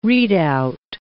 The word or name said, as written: readout